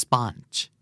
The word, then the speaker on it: sponge: sponge มันจะออกเสียงกึ่งกึ่งระหว่างออกกับอะนะครับ sponge